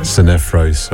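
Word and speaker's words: Sinephro: After the Andre was Nalas and Infro. No, that was not how you say it, is it? How do you say it? – Sinefro.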